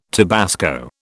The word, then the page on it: tabasco: British English